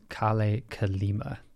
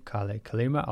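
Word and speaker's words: Kalle Kalima: And this guitarist is Kale Kalima. That's his name, Kale Kalima. I'll spell it for you. His first name, Kale, is K-A-L-L-E, and Kalima, K-A-L-I-M-A.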